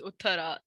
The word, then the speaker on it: Uthara: Yeah, so my name is Uthara. The H is not that pronounced by a lot of people, which I don't really care anymore.